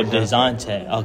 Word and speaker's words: Dejounte: Devontae Murray, bro. – DeJounte. – I can't even say his name. Is it DeJounte or DeJounte? – With the J. – Oh, DeJounte.